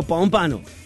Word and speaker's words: Pompano: Desde Pompano Beach, Pompano, se dice Pompano, Pompano.